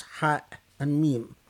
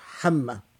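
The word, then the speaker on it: Hamma (حم): but that said that Hamim is actually not supposed to be pronounced as two letters, Ha and Mim, but it's supposed to be pronounced as a word, Hamma. Hamma is an actual word.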